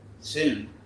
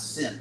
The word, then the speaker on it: syn: So the S-A-N in Sanskrit became S-Y-N, soon, which they, the Greeks pronounce soon, and we pronounce sin, like synthesis.